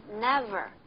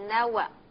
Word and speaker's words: never: 对了，是 never 不是 never。